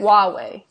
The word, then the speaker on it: Huawei: Huawei, I think. – Huawei, yes, because of the Huawei issues.